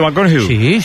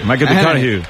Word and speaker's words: McConaughey: Mateo McConaughey. – Sí. – Hay que ir corrigiendo la pronunciación. – McConaughey.